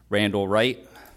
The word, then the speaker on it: Randall Wright: Randall Wright, R-A-N-D-A-L-L-W-R-I-G-H-T.